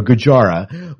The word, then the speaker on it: Gojira: Gohara, is that the way he pronounces it? He pronounces it an A-H. Do you know, Miles? – Sounds good to me. – Yeah, or Gojira was one of his top 5 classic sci-fi movies.